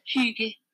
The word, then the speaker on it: hygge: I'm not sure if you heard that, but it was Hygge.